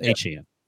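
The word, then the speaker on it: Achane: Sorry, that's how he was pronounced, Achan.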